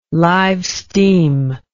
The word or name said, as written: live steam